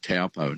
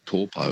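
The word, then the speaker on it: Taupō: When I was in New Zealand, there was Lake Taupo. Now it's called Taupo. – Taupo, yeah, that's right. It's just the vowel pronunciations are a bit different. They don't really have that ow sound, like cow, meow.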